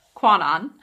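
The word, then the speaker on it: Kannon: It's actually pronounced like quack, quack. Quack, quack. So it's Quanon, the goddess of mercy, and at the temple of Gongan, which is a manifestation of Buddha in the form of a Japanese kami or divine being.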